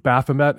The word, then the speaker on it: Baphomet: There's the statue of Baphomet. Am I pronouncing that correctly? – Yes.